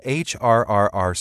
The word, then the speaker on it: HRRR: Scientists have been forecasting where this smoke will end up 48 hours ahead of time with an experimental model called HRRR smoke, pronounced HRR, from the National Oceanic and Atmospheric Administration.